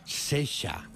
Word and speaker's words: Saoirse: Saoirse, que mira que en inglés y pone aquí Sesha.